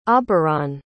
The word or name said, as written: auberon